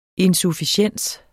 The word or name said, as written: insufficiens